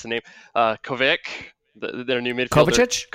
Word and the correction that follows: Kovacic: Kovacic, there we go.